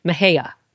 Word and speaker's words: Mexia: Mejia. – Mejia? – Yes, okay. It's spelled Mexia, But I watched Anna Nicole herself say it. Yeah. And if she said Mejia, I'm going to say Mejia.